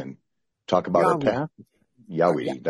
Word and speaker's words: Jaweede: But, you know, Yah is yes in German. Weed, of course, is weed. And then D-E, because it's spelled J-A-W-E-E-D-E, capital D and E.